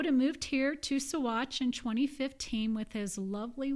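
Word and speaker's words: Saguache: Well, and that's how you pronounce it, everybody. Sawatch.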